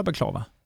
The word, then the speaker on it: baklava: Är det så man uttalar baklava? – Eh, ja, därom tvistar de lärda lite grann. Eh, baklava eller baklava har man hört. Eh, baklava. Men eftersom Tarektela säger baklava på skånska så kör vi på det.